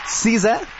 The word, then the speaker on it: SZA: Se agarró un nombre artístico muy icónico, Sisa, que de hecho ella ya explicó que se pronuncia así porque es Sisa, su nombre se escribe S-Z-A.